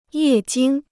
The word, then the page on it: 液晶: yè jīng